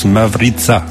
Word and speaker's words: Mavrica: Et il y en avait une qui était en slovène qui s'appelle Mavrika, qui se prononce Mavrica en vérité. Mais nous on l'a gardé à la consonance française puisque de toute façon on est en France ici.